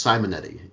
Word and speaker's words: Simonetti: Composer, Claudio Simonetti, is that how you say it, I guess? – Simonetti? I believe it's Simonetti.